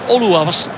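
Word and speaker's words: Oluwaseyi: De kopbal van Oluwase, moeilijke naam, maar zo moet ik het juist uitspreken. Tani Oluwase, die de voorzet van de nieuwe man daar op links, Jacob Scheffelberg, wist in te koppen.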